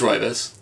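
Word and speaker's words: Roivas: Roivus? Or Rovius? I always said Roivus. – It's Roivus. That's how they say it.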